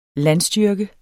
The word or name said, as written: landstyrke